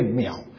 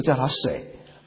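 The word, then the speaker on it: 淼: 我有一个同学，他的名字里面一个字就有三个水，三个水加起来念秒，我们以前都不知道怎么念，都叫他水。他说，哎，不念水，三个水就不叫水，三个水叫做秒。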